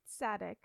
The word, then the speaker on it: Saadik: I mean, it's spelled S-A-A-D-I-K. – Sure. Yep. – Yeah. – Yep. – So according to Sadik, sounds like sad dick.